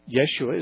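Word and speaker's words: Yeshua (ישוע): Yeshua is the normal Hebrew pronunciation for the name that in the New Testament is translated into Greek as Jesus or Jesus.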